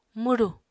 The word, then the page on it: mwru: Apatani